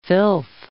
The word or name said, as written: filth